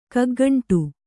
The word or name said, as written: ಕಗ್ಗಂಟು